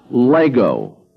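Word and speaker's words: Lego (λέγω): Present tense for I say, it's pronounced Lego.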